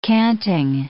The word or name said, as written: canting